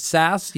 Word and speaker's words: SAS: We pronounce it as SAS. You know how it is. You know, it used to stand for something. Now it's just SAS. We pronounce it just as SAS.